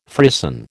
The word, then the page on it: frisson: American English